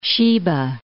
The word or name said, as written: Sheba